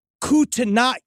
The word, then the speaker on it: Kootenai: Now, this came from Kootenai County. I believe that that's how it's pronounced. – I'm sure you nailed it.